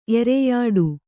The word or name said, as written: ಎರೆಯಾಡು